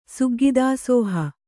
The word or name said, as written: ಸುಗ್ಗಿದಾಸೋಹ